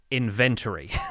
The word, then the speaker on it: inventory: British English pronounces it with three syllables, inventory. American English pronounces it with four syllables, inventory. I say it inventory. So I put the emphasis on the second syllable, and I get very peeved when everyone comes into my YouTube comments and tells me I pronounce this word wrong.